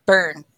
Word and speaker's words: Bern: Bern? – Bern. Yes, Bern.